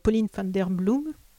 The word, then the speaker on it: Pauline van der Bloem: Et par ce petit décalage qui revient ici et là, tout au long du roman, je pense aussi à un paragraphe sur Pauline van der Bloem, on prononce comme ça ? – Oui, c'est bien, ouais.